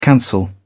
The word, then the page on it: cancel: British English